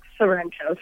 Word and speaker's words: Sorrento: Sorrento.